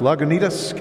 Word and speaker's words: Lagunitas: We got Chris from, is it pronounced Lagunitas, California? – Oh, yes.